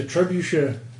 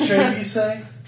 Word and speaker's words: trebuchet: You can make a trebuchet. – Yeah, exactly. – Except for your intelligence is 8. – Is that the correct way to say? – Pronounce it. – No, it's trebuchet.